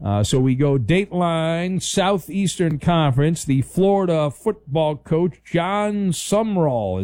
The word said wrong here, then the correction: Sumrall: Is that how you say it? He's new. He became part of S-U-M-R-A-L-L. – I know, it's weird. It is Summerall, though. – Is it pronounced Summerall? – Yeah. – I feel like there's a letter missing. – It is. It's not spelled right, but that's how it's pronounced.